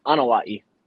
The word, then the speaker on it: Anoa'i: Is it Anoa'i? – So it's Anoa'i. – Anoa'i. Gotcha. Yeah. Close.